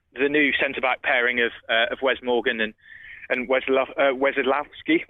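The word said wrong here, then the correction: Wasilewski: James will be able to correct me on the pronunciation of that, because he writes about him and spells his name a lot more than I do in my job. So it's Vasilevsky, because Nigel corrected me.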